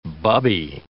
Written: bubby

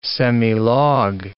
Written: semilog

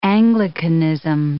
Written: Anglicanism

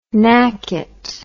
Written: nacket

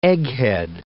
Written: egghead

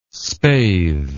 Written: spathe

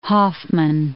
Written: Hoffman Estates

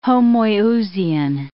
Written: homoiousian

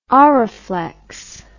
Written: Oraflex

